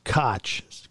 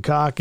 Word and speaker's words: Koch: I think it's pronounced Koch, K-O-C-H. Gone downhill. The same story as asswad and cock in it.